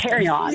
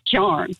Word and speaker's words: carrion: But charn. It's carry on. – Really? C-A-R-R-I-O-N? Is that how you spell it? – I think so. – Okay. – Yeah, but I had never heard it pronounced that way because, all my people always said charn.